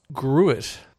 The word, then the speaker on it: gruit: Originally, they got that sort of floral, fruity bitterness that we now see coming from the hops from gruit. It may also be pronounced groot, but I refuse to call it groot. We are not groot.